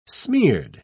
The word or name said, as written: smeared